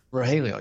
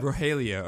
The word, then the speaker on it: Rogelio: Rogelio, is that right? – Rogelio, yep. – Rogelio, okay.